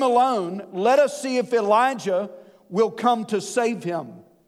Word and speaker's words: Elijah: The name Elijah, they would have pronounced Eliyah. It sounds like Eli, the word for my God in Jesus' language.